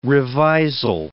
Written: revisal

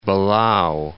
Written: Belau

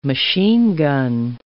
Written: machine-gun

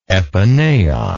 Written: epinaos

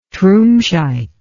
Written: trumscheit

